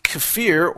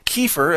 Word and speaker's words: kefir: Raw fermented milk, also known as kefir or kefir, as it's sometimes pronounced, that's K-E-F-I-R, can also give you all kinds of health benefits.